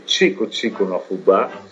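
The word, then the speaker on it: Tico-Tico no Fubá: anzi Chico Chico no Fubá, perché i brasiliani pronunciano così il Chico Chico, e una composizione del 1917 fatta da uno dei padri fondatori della canzone brasiliana, qual era Zequinha de Abreu.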